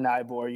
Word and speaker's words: Eibar: So that team would be, in this case, Eibar. I'm not sure if I'm pronouncing that. Yeah, Eibar.